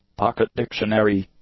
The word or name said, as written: pocket-dictionary